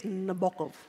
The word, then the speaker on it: Nabokov: Crowd reckons his name's pronounced Nabokov. – It is Nabokov.